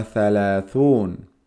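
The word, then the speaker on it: ثلاثون: Zalazun es como zalaza.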